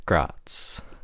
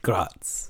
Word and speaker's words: Graz: In 1870, he studied physics and engineering at the Technical University at Graz. I'm saying that wrong. But Graz.